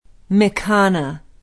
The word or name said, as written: Mikana